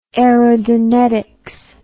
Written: aerodonetics

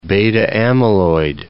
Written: beta-amyloid

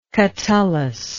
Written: catullan

